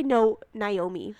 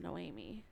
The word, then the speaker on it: Noemi: Noemi. Yes. I always want to say Naomi, but it's not. – So it's Noemi.